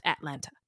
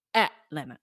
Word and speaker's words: Atlanta: By the way, it's not pronounced Atlanta. It's pronounced Atlanta. – Atlanta. – Don't say the T's.